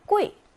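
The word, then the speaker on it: 꾸이 (贵): 꾸이, 사성으로 읽고요.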